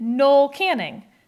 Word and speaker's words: Noel Canning: Who's on strike, and they pronounced it Noel Canning. So I feel like that whole debate's put to rest, and I felt really good, and I slept well that night, knowing how to pronounce Noel Canning.